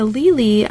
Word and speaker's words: Lely: Is that how you pronounce his name? Lely? – Correct.